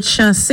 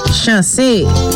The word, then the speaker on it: chanse: And your bonus Creole word for today is the word chasse. Chasse. C-H-A-N-S-E. Accent is on the E.